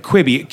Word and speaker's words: Quibi: So I'm saying that right. A Quibi. Q-U-I-B-I.